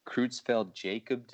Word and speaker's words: Creutzfeldt-Jakob: Creutzfeldt-Jakob disease, which is basically... – Yeah, you pronounced that right.